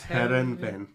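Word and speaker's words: Heerenveen: Van La Parra from... I can never pronounce this. – Herenveen. – There we go, on a free.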